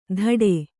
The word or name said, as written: ಧಡೆ